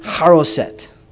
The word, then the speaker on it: charoset: C-H-A-R-O-S-E-T. That's a Hebrew word, and it's pronounced haroset.